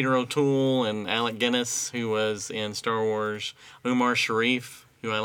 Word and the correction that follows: Omar Sharif: Omar Sharif.